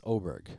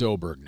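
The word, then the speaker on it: Åberg: We got a video sent to us and said, this is how I want TV producers to say my name, Ludwig Oberg. – Now it's Oberg. – It's Oberg now.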